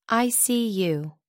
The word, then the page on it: icu: American English